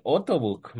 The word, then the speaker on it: Ottobock: embajadora de Ottobook,